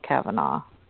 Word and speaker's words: Caomhanach: It's spelled C-A-O-M-H-A-N-A-C-H. – Hmm. – And I guess the pronunciation is somewhere along the lines of Kavanaugh.